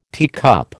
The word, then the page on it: teacup: American English